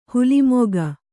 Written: ಹುಲಿಮುಖ

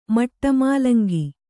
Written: ಮಟಮೂಲಂಗಿ

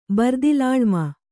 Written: ಬರ್ದಿಲಾಳ್ಮ

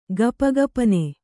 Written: ಗಪಗಪನೆ

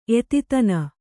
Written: ಎತಿತನ

